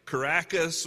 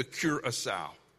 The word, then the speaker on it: Curaçao: So Bethany likes to tell people, because when they see the word Curaçao, they don't know how to pronounce it. And oftentimes it comes out with like Caracas or you know. And so I want to teach you the way that Bethany teaches people how to pronounce the word Curaçao. It's one of those mnemonic devices that if you want to have bacon, you have to cure a sow.